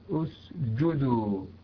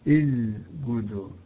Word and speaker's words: اسجدوا: طيب، إذا سكنت السين وجب همسها وإلا انقلبت زايا في مثل قوله تعالى اسجدوا اسجدوا، البعض ينطقها الجدو الجدو هذا خطأ، خطأ ولحن جلي، يجب أن نتدرب على النطق الصحيح لهذا الحرف وأن ننطقه نطقا صحيحا سليما أثناء التلاوة.